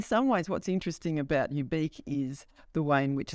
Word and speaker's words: Ubique: Ubique which for people listening, ubique is spelt like unique, but with a B instead of an N.